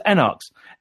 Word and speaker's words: Enarx: So it's Enarx. It's kind of it's kind of made up, kind of pretend cod Latin.